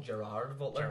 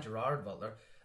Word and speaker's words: Gerard Butler: as the Americans like to call him, Gerard Butler. – Gerard. – Like, just fucking say it right. Gerard Butler.